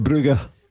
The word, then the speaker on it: Brugge: And it's Club Brugge. – Brugge, you're right here. – Is it? What's the backstory there? Because I naturally would have gone Bruges as well. But is that the local pronunciation or something?